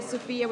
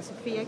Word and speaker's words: Sofia: Sophia with F, Q-T, all together. Sophia Q-T. – Spell it? – S-O-F-I-A.